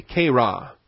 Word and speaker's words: chera: Second vocabulary, point B, is the kera. C-H-E-R-A, kera. That's the Greek chi, C-H-I. The long E is the eta. The rho is that P-looking thing. And then the alpha.